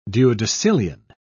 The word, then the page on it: duodecillion: British English